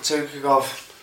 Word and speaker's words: Tukhugov: Tiagov, saying his name right? – Tukhugov. – Jesus Christ. Tukhugov, yeah.